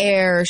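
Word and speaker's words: Aire: His name is Air. So if you saw it online, it's A-I-R-E. It's pronounced air, like the air you breathe.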